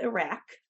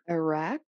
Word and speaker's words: Ajiraq: Iraq? – It's like irate. A-J-I-R-A-Q is Iraq, and the J is silent.